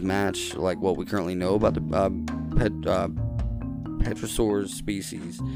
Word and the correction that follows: pterosaur: I hope I'm saying that right. Yeah, pterosaur? Yeah, pterosaur species, including large forearms supporting leathery wings. Yeah, pterosaur. I'm almost like a pterodactyl. You know, I pronounced the P before I messed that one up.